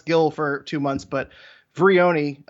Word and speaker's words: Vrioni: I imagine that when uh, now, first off, I believe it's Veroni, right? Uh, I got. – You're right. I had my. You're right. My, it is. It is Vrioni.